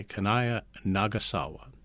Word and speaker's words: Kanaya Nagasawa: and Samurai Kanaya Nagasawa.